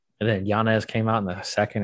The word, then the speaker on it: Yanez: How do you say Adrian's last name? Yazes? – Yanez. Adrian Yanez.